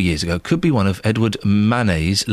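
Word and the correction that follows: Édouard Manet: last works. Did I say that properly, Catherine? Because you're a proper art student, aren't you? – Edouard Manet. Everyone knows what you mean.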